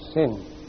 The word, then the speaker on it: sin: If you put it there, it's called sin.